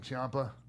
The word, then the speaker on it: Ciampa: Hey, so is there a better heel in wrestling than Ciampa? Did I say that right? – Yeah.